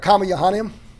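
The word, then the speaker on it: Comma Johanneum: Comma Johannium. – Johannium. – They pronounce the Y sound for the J.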